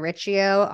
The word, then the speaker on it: Riccio: You can find me on Instagram at Sabrina Riccio, R-I-C-C-I-O. Like Gucci, like everyone always says my name wrong, but it's like, you guys have no problem saying Gucci, right? It's the same shit. CC makes a ch sound, okay?